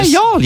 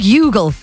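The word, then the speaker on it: Youghal: Yeah, Yall, yeah. – It looks like Yugle. – It does. – It's spelled Yugle like Dougle, but it's Yall.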